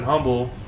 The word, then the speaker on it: Humble: It was at a cigar shop in Humble? What's the correct? Humble? – Either way. – If you're from Texas, you don't pronounce the H.